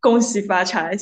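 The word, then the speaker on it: Gong Xi Fa Cai: I don't know if I'm saying it correctly, but I've lived in Singapore and I know the greeting. So yeah, it is Gong Xi Fa Chai.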